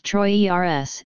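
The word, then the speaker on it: Treuer: Dr. Anton Truer, pronounced Troy ERS, is professor of Ojibwe at Bemidji State University and author of 19 books.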